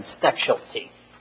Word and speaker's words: Stechschulte: Stechschulte. It's kind of like specialty.